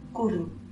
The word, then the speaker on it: cwrw: Eh, de hecho, voy a, voy a explicar cómo se escribe que se escribe C-W-R-W. No hay vocal. Compro vocal, gracias. No hay, ¿vale? Pero se pronuncia algo parecido como curru.